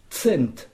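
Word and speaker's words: cent: Alors, ce son, on le connaît guère que dans la mouche, tsetse, mais finalement, c'est pas bien difficile à prononcer, comme s'il y avait un T et un S. Tsent.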